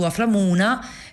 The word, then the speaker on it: Aflamuna: Fino al 6 maggio su Aframuna che eh, si scrive così come lo pronuncio,